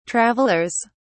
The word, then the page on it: travellers: British English